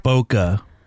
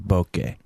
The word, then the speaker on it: bokeh: People pronounce it bokeh. But it should be pronounced bokeh. – Okay. – Sake, bokeh, but whatever.